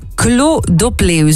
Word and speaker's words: Clos d'Opleu: Jij bent zelf ook wijnmaker van Clos d'Opleu, zo zeg ik dat goed, hè? – Zo zegt u dat correct, ja.